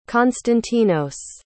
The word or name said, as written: konstantinos